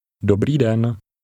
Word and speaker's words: Dobrý den: Remember to stress the first part of Czech words. Say again, hello. – Dobrý den.